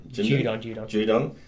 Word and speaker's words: Judon: Matthew Judon, is that right? – Judon.